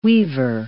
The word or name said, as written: weaver